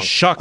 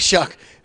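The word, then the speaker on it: Shough: And then Schoff, is that how you say his name? I think the quarterback, right? I always do this wrong. – Shuck, for some reason, despite how it's spelled.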